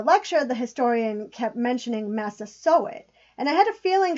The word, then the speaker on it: Massasoit: She told me that based on the research they've uncovered, Massasoit is the correct pronunciation.